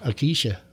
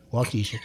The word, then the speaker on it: Waukesha: Waukesha. Waukesha.